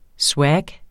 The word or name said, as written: SWAG